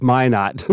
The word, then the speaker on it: Minot: And so he said, well, oftentimes people mispronounce it because it's Minot.